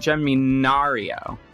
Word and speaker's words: Germanario: Yeah, just put a little Italian spin on it. Germanario.